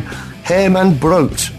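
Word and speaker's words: Herman Brood: And this is a track called Never Be Clever by an act originally called Herman Brute, who, thanks to Wikipedia, I discovered, you pronounce his name Herman Brute.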